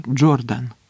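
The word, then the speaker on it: Jordan: Назва річки Йордан по-англійському вимовляється Джордан.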